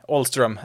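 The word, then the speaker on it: Ahlstrom: Den ena var Robbie Ahlström. Nej, Ahlström är väl det eh, riktiga uttalet där,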